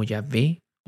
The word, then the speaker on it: Yahvé: No tiene vocales, así que cuando tratamos de pronunciarlo, suena como Yahvé o Jehová.